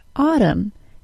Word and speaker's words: autumn: and the silent N in words that end in the letters MN. All of that is in the word autumn, and it is great stuff.